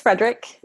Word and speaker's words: Fraedrich: It's Fraedrick.